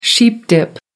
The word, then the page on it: sheep dip: American English